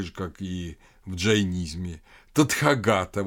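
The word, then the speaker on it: Татхагата: Тадхагата – это тот, кто достиг, или тот, кто пришел. Если делать иначе ударение, то получается тот, кто ушел.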